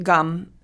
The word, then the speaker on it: gam: And the word gum is spelled G-A-M, but pronounced gum, and it's just Ganesha's Bija mantra.